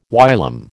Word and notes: whilom: American English

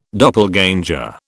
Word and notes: doppelganger: British English

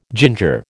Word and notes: ginger: American English

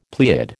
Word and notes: pleiad: American English

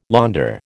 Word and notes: Länder: American English

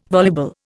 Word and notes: voluble: Indian

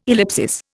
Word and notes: ellipsis: Indian

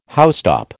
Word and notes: housetop: American English

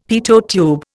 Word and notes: Pitot tube: Indian

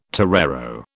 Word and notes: torero: British English

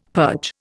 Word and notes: purge: Indian

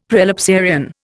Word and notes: prelapsarian: Indian

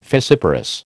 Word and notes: fissiparous: American English

